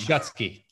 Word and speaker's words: Shutsky: Shutsky. Like two words, shut and ski.